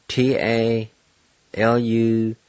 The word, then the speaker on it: talugisgi: And it's spelled T-A- L-U-G-I-S-G-I. Again, T-A-L-U-G-I-S-G-I. Number three, tiny. And it's spelled U-S-D-I-I. U-S-D-I-I.